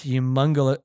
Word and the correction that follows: homunculus: is that how you say it? – Homunculus? – Homunculus.